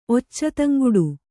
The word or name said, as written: ಒಚ್ಚತಂಗುಡು